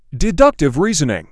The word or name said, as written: deductive reasoning